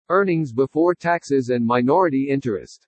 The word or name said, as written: EARNINGS BEFORE TAXES AND MINORITY INTEREST